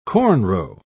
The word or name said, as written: cornrow